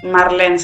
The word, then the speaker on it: Marlene: Marlene se dice. Se escribe Marlene como las manzanas. No sé si ahí se conocen las manzanas Marlene.